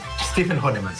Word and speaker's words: Stephen Hahnemann: gravaram uma outra cantata do Quinto Sol, com a letra do Stephen Hahnemann. É assim que pronuncia? – É assim, Stephen Hahnemann, sim.